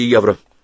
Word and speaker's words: евро: «Ден, каза, да дади 50 000 евро!» В слове «евро» ударение было на последний слог,